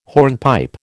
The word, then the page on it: hornpipe: American English